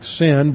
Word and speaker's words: Sin: We spell it S-I-N like sin, but it would be pronounced Sin.